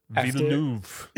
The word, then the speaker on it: Villeneuve: How do you pronounce it? – Villeneuve.